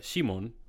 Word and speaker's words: Shimon: So in the Greek, they would not have a sheen. So it would be Simon. It would say Simon would be the way to pronounce it. Now, that would be cool too. – In Hebrew, it would have been Shimon.